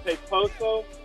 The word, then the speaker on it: Peposo: It's a dish called Peposo. It's an Italian dish. It's spelled P-E-P-O-S-O, Peposo.